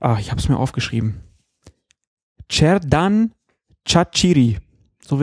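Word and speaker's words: Xherdan Shaqiri: Auf der letzten Silbe wird betont im Albanischen und deswegen heißt der Mann dann Cerdan Chachiri irgendwie.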